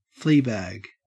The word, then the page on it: fleabag: Australian English